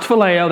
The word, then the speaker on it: phileo: Pronounced phileo, that's the verb form of it.